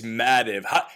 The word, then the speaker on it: Mativ: I've heard Mativ, I've heard Mativ. Yeah. It's Mativ. How do you go from Schweitzer Madwi, rebranded to SWM, throw in a little Nina, and come out with Mativ?